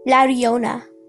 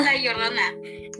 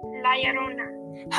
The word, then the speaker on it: Llorona: So this is how I pronounce it. La Riona. That's how I pronounce it. – La Llorona. La Llorona. – Yeah, see, she does it perfectly.